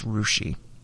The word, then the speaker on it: Russia: Yes, I know it's spelled Russia, but it's Rushi.